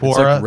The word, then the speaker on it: Quora: Quora, like that website?